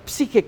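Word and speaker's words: psije (ψυχή): Por lo tanto, esa palabra psije, que va a ser clave acá, la pronunciación en griego, en el mundo de la mitología griega, sí, significa un ser que anda caminando, ahí anda penando, anda una sombra.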